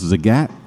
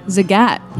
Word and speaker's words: Zagat: It's pronounced Zagat. – Zagat, like the cat.